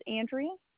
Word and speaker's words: Andrea: First name is Andrea. – Spell it for me. – A-N-D-R-E-A. – And your last name? – Human. H-U-H-M-A-N.